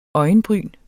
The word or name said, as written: øjenbryn